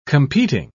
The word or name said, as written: competing